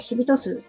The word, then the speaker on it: 紫微斗数: こちらの背景にせし、えーと、設定させていただいた、えーと、シビト数のね、アリト、アリさん。はい。えっ、ー、と、これ、紫、えっ、ー、と、微妙の B、うん、北斗七星の塔、数と書いて、シビト数